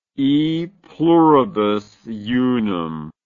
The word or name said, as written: e pluribus unum